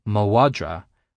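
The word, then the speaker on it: mo mhadra: Dog is madra, my is ma, but my dog is mawadra, with the MH sounding like a W.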